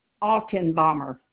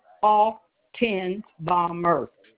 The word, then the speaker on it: Altenbaumer: Altenbaumer. I'll spell that one for you. It's A-L-T as in Tom, E-N, B as in boy, A-U, M as in Mary, E-R. Altenbaumer.